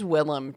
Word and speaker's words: Willem: It is Willem Dafoe. – Willem. – Yes. Willem.